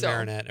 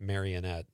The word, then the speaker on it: Marinette: Well, I live in Marinette. Everybody pronounces it Marionette.